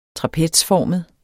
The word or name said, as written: trapezformet